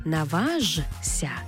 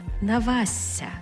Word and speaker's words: наважся: Тобто не «наважся», а «навася». Також пишемо «шся», а вимовляємо «ся».